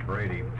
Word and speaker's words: Brady: My name is Brady. I'm a physician. It's easy now. I introduce myself. This is like in the Brady Bunch, and everybody can immediately spell it.